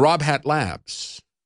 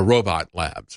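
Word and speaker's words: RobHat Labs: I hope you're ready to be checked by Secure Democracy and Rob Hat Labs, or Robot Labs is actually, I guess, the way you pronounce that. It's R-O-B-H-A-T-L-A-B-S.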